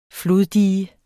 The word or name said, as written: floddige